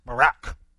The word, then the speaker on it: barak: I had a seminary professor who said that's the way that the ancient Hebrew word is pronounced, Barak.